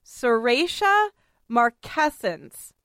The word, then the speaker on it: Serratia marcescens: Phonetically, with the accented syllables in caps, it is Serratia marcescens.